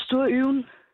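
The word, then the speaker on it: STU YWN: Ja, altså det udtales Sturøven.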